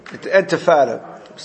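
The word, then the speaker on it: Intifada: Ulachain, bantef, Entifada, how do you pronounce it? Entifada.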